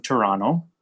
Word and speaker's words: Toronto: So you are a product design lead at Scotiabank in Toronto. Notice how I did not pronounce the second T.